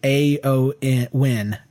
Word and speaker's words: Eowyn: She says her name in the forums is Lady Eowyn. It's pronounced Lady A-O-N. A-O-N. – A-O-N.